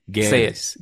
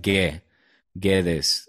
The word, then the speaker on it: Guedes: Guedes. The U you don't pronounce. It's like Guedes. You know, like que, you don't say que.